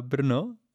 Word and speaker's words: Brno: Ja, bestimmt nicht so cool wie Prag oder Brno.